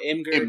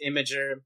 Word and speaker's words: Imgur: I call it Imgur because it looks like it says Imgur, but it's actually pronounced Imgur.